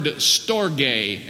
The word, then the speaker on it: storge: Then there is a second word for love, and that is the word storge, and we spell that in the transliteration into English S T O R G E.